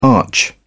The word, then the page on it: arch: British English